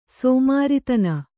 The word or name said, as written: ಸಮಾರಾಧನೆ